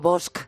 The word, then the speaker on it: Bosch: Digo Bosch. Porque es como siempre aprendí a pronunciarlo.